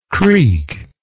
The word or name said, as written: creakingly